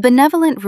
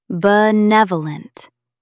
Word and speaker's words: benevolent: A benevolent ruler looks out for the whole community. Stress the second syllable. Benevolent. Say it aloud. Benevolent.